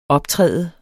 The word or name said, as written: optræde